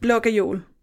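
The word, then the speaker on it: Blå Gajol: B-L-O, which is an A with a dot over, or it's just two A's. And then Gejol, which is G-A-J-O-L. – And how do you say it? – Blågejol. – Blågejol. So there's like a vowel sound in between the G and the J, even though there's no vowel. – Oh, no, there is a vowel. G-A-J-O-L. – G-A-J-O-L.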